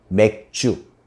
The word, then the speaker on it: 맥주: 比如说 맥주，写在汉字呢是麦酒，就是我们说的啤酒，发音有点像台语的白阿酒。